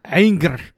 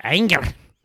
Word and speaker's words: Ehinger: Well, I mean, it's German, so I mean, you need to make it sound like you're mad. Ehinger. – Yeah, okay. Yeah, Ehinger.